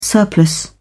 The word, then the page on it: surplus: British English